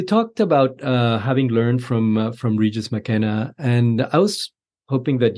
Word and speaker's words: Regis McKenna: But I'm curious, in the book, you bring up Regis McKenna. I don't know if that's how you pronounce his name. – Yep, that's right.